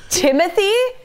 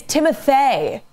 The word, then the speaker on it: Timothée: And she was like, Timothy? She was like, it's Timothée. It's not Timothy.